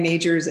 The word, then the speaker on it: Magers: Just be sure you spell my last name is spelled M-A-G-E-R-S. My husband has a spelling problem, but Diane Majors, and find me on LinkedIn, or you can go to CXPA, go to the directory if you're so inclined.